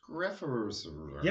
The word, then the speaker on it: Skrefsrud: Let me spell this for you so you at home can like put this image in your head and you can judge me for my pronunciation. But it's SKREFSRUD. Skrefsrud. – I think if you add one more syllable, you'll have it. – Skrefsrud.